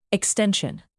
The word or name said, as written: extension